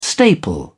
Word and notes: staple: British English

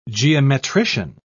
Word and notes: geometrician: British English

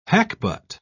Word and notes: hackbut: British English

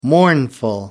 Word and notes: mournful: American English

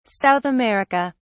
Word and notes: South America: British English

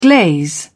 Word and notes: glaze: British English

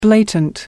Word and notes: blatant: American English

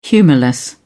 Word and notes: humourless: British English

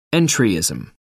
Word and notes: entryism: American English

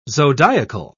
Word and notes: zodiacal: British English

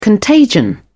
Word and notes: contagion: British English

